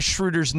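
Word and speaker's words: Schröder: And it basically said, hey, Mason, tell Ireland he's mispronouncing Dennis Schroeder's name and it annoys me.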